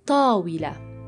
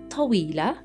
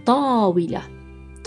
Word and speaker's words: طاولة: It's not طويلة, which means tall or long, not طويلة, طاولة. There is an emphasis on the first a sound in table. Table is طاولة.